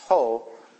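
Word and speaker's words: ho (ὁ): If you ever look in Greek, it just looks like an O, but it's actually pronounced ho.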